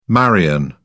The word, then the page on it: marian: British English